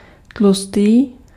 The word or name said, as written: gros